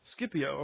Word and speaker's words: Scipio: The Roman general Cornelius, and help me with the pronunciation if you know it, I think it's Scipio. I know that's wrong, but how does it go? Scipio, okay. That sounds good to me.